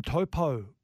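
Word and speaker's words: Taupau: They've got Martin Topo is the way that he wants to be pronounced now. – It's not Topo anymore, it's Topo.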